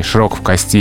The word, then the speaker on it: широк в кости: Но в устойчивом выражении «широк в кости» и «узок в кости» только такое ударение. Только на последнем слоге. – Mm-hmm. – Поэтому говорят правильно. Широк в кости и, соответственно, узок в кости.